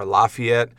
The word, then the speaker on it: Lafayette: It's spelled like Lafayette or Lafayette, but in North Mississippi, they say Lafayette, okay? If you say Lafayette, they'll just look at you funny.